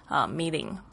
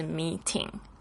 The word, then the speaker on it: meeting: 还有像会议 meeting 他就会念成这个、 meeting，不是 meeting。